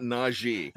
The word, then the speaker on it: Najee: That's how he wants it pronounced. Not Najee. He wants it Najee. It's not Najee. – It's not a Z sound in there.